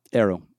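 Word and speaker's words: Aero: That's the best way to remember how to pronounce it. Just think of Aerosmith. – Right, Arrow.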